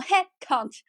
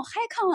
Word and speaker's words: Head Count: 等我真的查一下，它叫 Head Count 之后，我就突然一懵，我心想，那你这个发音是不对的呀，你这个发音应该用 Head Count，你不能用 High Con 啊。